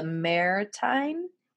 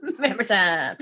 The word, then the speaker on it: Mamertine: It's called the Mamertine. – It rhymes with hammer time. – Mamer time.